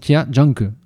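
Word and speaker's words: Jia Zhangke: Alors, je précise la prononciation et l'orthographe. L'orthographe, c'est J-I-A, plus loin Z-A-N-G-K-E, et on dit donc Tia Janke.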